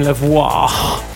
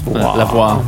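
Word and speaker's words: Lavoix: And there's Danny Beard and Drag Queen Lavoie. I hope that's how you pronounce it. – It is, yes. – V-O-I-X. – Lavoie.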